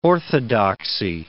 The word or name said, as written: orthodoxy